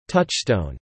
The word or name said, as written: touchstone